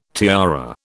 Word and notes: tiara: British English

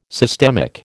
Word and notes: systemic: American English

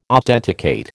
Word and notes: authenticate: American English

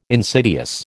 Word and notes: insidious: American English

